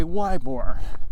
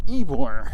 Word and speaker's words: Ybor: If you were to pronounce it in American letters, you'd say Ybor or Ybor, but it's Ybor, almost like Igor, the Russian name, but it's Ybor, Ybor City.